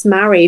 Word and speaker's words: Marie: So Marie, I would say it's spelt Marie, but it's pronounced Mary.